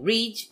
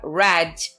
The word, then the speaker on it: read: Então, a escrita do verbo é a mesma, só que a pronúncia vai de read para read no simple past.